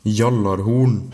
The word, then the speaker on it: Gjallarhorn: ou comme le prononce mon ami viking... – Gjallarhorn.